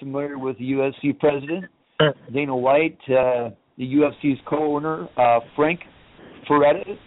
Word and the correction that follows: Fertitta: that's his name, I'm pronouncing that correctly? – Yeah, Frank or Lorenzo Fertitta, yeah, they're the owners.